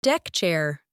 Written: deck chair

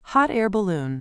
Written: hot-air balloon

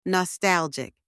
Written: nostalgic

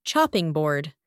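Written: chopping board

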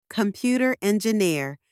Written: computer engineer

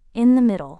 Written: in the middle

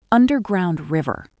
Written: underground river

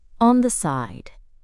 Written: on the side

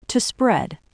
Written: to spread

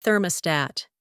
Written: thermostat

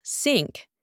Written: sink

